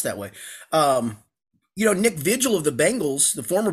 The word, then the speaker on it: Vigil: It's pronounced Vigil. No, he said it's fine to call him Nick Vigil.